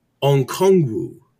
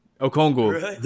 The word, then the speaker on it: Okongwu: Onyeka Okongwu. – That was really good. That was actually right. No, you got it. Okongwu. Without the N. Okongwu.